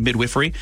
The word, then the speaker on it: midwifery: The director of this midwifery department, that's how you say it, isn't that fun, midwifery, at York, it's a teaching hospital, predicted that birth rates will rise if England enjoys a successful tournament.